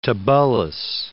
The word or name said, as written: Tibullus